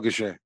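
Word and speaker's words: Gausha: Am I saying that right, Goucher? – Yeah, Terrell Goucher.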